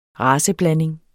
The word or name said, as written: raceblanding